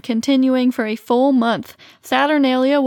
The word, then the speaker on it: Saturnalia: Saturnalia? – Mm-hmm, Saturnalia.